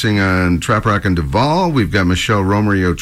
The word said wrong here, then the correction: Romary: Trio. Did I say it right? – Romery Trio.